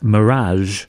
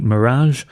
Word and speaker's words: Mi3raj: I think they're supposed to just be pronounced Mirage, but it's spelt M-I-3-R-A-J, but seems to be Mirage.